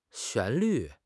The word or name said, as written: 旋律